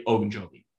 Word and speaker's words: Ogunjobi: That's going to be a big problem for Cincinnati, especially because they lost their number one run stopper last week, Larry Ogunjobi. I think I said that right.